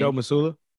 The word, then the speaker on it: Mazzulla: Joe Mazzulla.